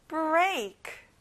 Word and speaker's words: break: Break. A 발음이 나요. Break. Correct.